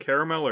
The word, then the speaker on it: caramel: Is it caramel or caramel? – So, no, caramel is how you pronounce it.